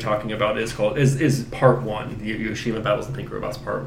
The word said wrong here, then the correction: Yoshimi: Or I think I'm saying that right. It's Yoshimi.